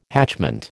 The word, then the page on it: hatchment: American English